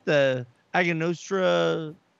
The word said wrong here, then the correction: Angostura: Angostura. – Angostura.